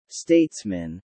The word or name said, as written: statesmen